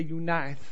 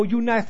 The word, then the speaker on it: Eunice: So Eunice, but you got to say it like this. You got to say Eunice. So Eunice. Oh, you say, oh, you nice and young, so you the mom.